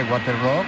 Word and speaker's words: Waterloo: Pues no, desde luego, pero ha hecho mucho daño en la pronunciación de Waterloo, que se pronuncia igual que Feijóo.